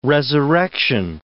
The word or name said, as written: resurrection